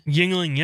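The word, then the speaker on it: Yuengling: Recently, however, I've discovered, I don't even know how to say this, Yingling? – Yingling, yeah.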